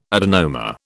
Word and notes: adenoma: British English

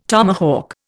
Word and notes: tomahawk: Indian

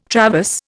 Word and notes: traverse: Indian

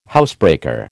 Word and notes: housebreaker: American English

